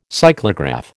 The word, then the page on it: cyclograph: American English